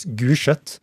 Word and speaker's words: Gulset: Ja, uttales, nei, skrives Gullsett. Uttales Gulset.